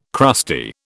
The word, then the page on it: crusty: British English